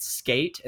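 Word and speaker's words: Sk8: And then we've also selected Skate, and that's spelled SK and then the number 8, but sometimes they put it horizontal so it looks like an infinity sign.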